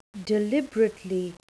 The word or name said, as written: deliberately